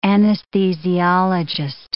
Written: anesthesiologist

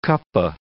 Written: cuppa

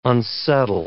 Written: unsettle

